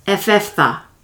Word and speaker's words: Ephphatha: Ephphatha.